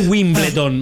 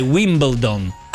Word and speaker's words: Wimbledon: Wimbledon. – Chi? – Wimbledon. – Wimbledon è tennis. Quello è un altro. Che tra l'altro non è Wimbledon, ma è Wimbledon.